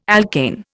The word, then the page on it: alkane: Indian